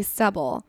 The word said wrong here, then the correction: subtle: Stubble? – Subtle.